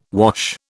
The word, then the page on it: wash: British English